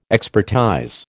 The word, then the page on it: expertize: American English